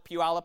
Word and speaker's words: Puyallup: I just call it Puyallup.